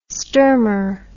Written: sturmer

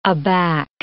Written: aback

